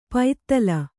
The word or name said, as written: ಪೈತ್ತಲ